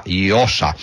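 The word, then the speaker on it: Llosa: Volendo fare una piccola battuta si potrebbe dire che eh, di premi Nobel se ne assegnano a Iosa, perché questo è il modo in cui si deve pronunciare per l'appunto il nome di eh, Vargas Iosa che eh, ha vinto ieri per la letteratura.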